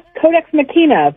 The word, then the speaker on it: Codex Machina: Her upcoming book is called Codex Machina. How am I pronouncing that, Heather? Pretty good? – Yeah, Codex Machina, but I'll take it either way.